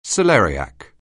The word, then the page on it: celeriac: anglais